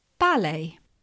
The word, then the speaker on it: ballet: Similar words that come from the French that have a T at the end that we don't pronounce. Ballet, B-A-L-L-E-T. In the last conversation with Danilo, I said the life of a ballet dancer is tough. The life of a ballet dancer is tough. In other languages, people pronounce the T at the end, but in English, we try to sound French and we don't pronounce the T.